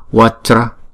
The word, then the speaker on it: vajra: Chữ J này chúng ta đọc là J, J. Ráp lại là hai âm là Vajra, Vajra. Chứ chúng ta không đọc là Vajra. Nếu mà chúng ta đọc Vajra thành ba âm rồi, đúng không? Chữ này là hai âm Vajra, Vajra.